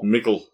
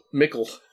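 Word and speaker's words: Mikl: And if you notice how Michael is spelled, it's M-I-K-L. – Mickle. – Mickle.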